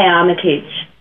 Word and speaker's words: Armitage: A lot of Americans say Armitage, but I say Armitage.